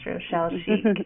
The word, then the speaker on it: Rochelle Schieck: It is a fun name to pronounce, Rochelle Schieck.